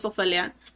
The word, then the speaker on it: Ofelia: So my name's Ofelia, and so, and it's, you know, I always get it pronounced Ophelia, Ophelia, Ophelia, a bunch of ways. It's spelled incorrectly because in Spanish we don't use the PH, right? Because I would be Opelia, and that's not how you say my name.